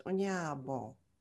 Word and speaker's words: Onyeabor: And the last name is pronounced Onyeabor. And it took me 10 years to learn how to say that.